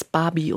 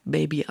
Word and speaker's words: Baby: Und meistens haben die das auch als Barbie und nicht Baby ausgesprochen.